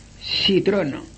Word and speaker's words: citrono: Limón, citrono, citrono. C, I, T, R, O, N, O. Citrono.